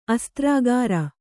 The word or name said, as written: ಅಸ್ತ್ರಾಗಾರ